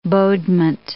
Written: bodement

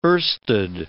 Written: oersted

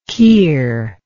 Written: kier

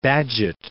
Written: Bagehot

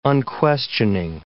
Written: unquestioning

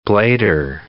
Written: blader

